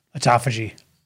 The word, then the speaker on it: autophagy: Autophagy.